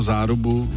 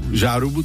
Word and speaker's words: Zárubu: Manžel po každém vyslovení Larsona, Petersona, infarktové stavy, nevím, co by bylo, kdyby měl pana Zárubu, Žárubu, tady píše Žárubu po ruce, jak ho přejmenoval.